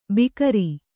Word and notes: ಭಿಕಾರಿ: bhikāri

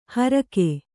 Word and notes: ಹರಕೆ: harake

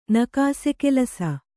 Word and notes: ನಕಾಸೆಕೆಲಸ: kannada

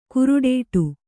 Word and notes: ಕಾರೊಡ್ಡು: kāroḍḍu

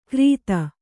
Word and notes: ಕರೀತ: karīta

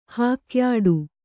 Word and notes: ಹಾಕ್ಯಾಟ: hākyāṭa; Kannada

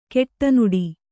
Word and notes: ಕಡುನುಡಿ: kaḍunuḍi

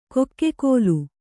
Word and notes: ಕೊಕ್ಕೆಕೋಲು: kokke kōlu

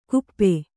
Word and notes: ಗುಪ್ಪೆ: kannada

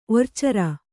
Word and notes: ಒರ್ಚೇರೆ: Kannada

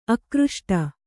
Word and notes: ಆಕೃಷ್ಟ: ākřṣṭa

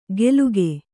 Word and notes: ಗೆಲುಗೆ: geluge; kannada

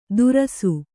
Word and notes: ದುರಸು: Kannada

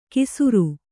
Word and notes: ಕಿಸುರು: kisuru

